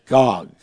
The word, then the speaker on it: Gog: G-U-G-U, in the Assyrian, which in English would be pronounced as Gog.